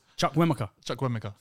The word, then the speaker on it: Chukwuemeka: Oh, just Chukwuemeka. I don't know why you added extra syllables. – Chukwuemeka.